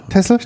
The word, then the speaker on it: Texel: Also, den Texel, oder wie wird es richtig ausgesprochen? – Tessel.